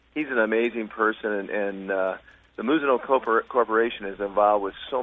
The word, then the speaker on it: Mizuno: You had dinner with, how did you pronounce that? Because here it's Mizuno Sports. – Well, it's lost in translation. It's Mizuno. – Mizuno.